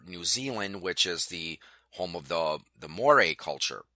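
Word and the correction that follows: Māori: And or Maori, I think it's pronounced Maori.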